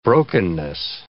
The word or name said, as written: brokenness